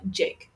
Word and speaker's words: JVKE: Jake. It's pronounced Jake.